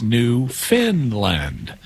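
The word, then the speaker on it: Newfoundland: It's spelled New Found Land, but it's pronounced New Finland, even though it's not located in Scandinavia.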